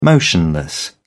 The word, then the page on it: motionless: British English